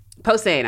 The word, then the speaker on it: Posehn: And I remember going to a comedy show with Brian Posner, which you probably know. – Posehn. – I'm getting his name wrong.